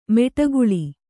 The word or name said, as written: ಮಡುಗೊಳ್ಳು